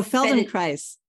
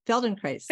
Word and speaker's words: Feldenkrais: I'm always bad at like pronouncing Feldenkrist method. – Oh, Feldenkrais. Feldenkrais.